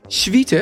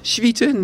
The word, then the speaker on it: Swieten: Het is namelijk het duurzame, luxe beddengoedmerk Swieten. Het staat hier nog speciaal. Uitspraak op zijn Nederlands. Swieten, net zoals Charles Swietert, zonder rut. Swieten 702.